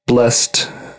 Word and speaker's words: Blessd: Blessed is the one I'm listening to right now. It's spelled B-L-E-S-S-D.